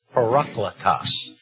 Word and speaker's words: Parakletos: Another, and I think the Greek pronunciation would be Parakletos. Parakletos. Some might say Parakletos, as I have done.